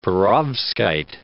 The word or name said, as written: perovskite